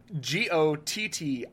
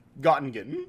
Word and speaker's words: Göttingen: Tell us how to say that right. – Göttingen. Göttingen. – I mean, you're probably as close as any of us will get.